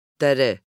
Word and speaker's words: Doire: So it's spelled D-O-I-R-E. – Dere. Dere. – Daddy? – I have no clue. No clue at all. It's D-O-I-R-E.